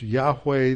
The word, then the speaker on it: Yahweh: This name that is pronounced Yahweh, that's the sacred tetragrammaton or the sacred four letters, uh, YHWH.